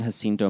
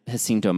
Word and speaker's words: Jacinto: I also want to go back and talk about the pronunciation of San Jacinto Mountains, which I think the locals say San Jacinto. Jacinto, or I've also heard Jacinto. The point being that that's all terrible Spanish. – Probably Jacinto, because they pronounce their, no. They pronounce J's like H's, right? – Well, it should be Jacinto.